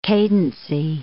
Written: cadency

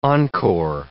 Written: encore

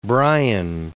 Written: Bryan

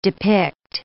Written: depict

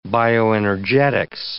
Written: bioenergetics

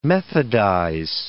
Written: methodize

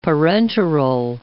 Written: parenteral

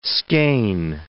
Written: skean

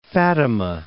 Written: Fatima

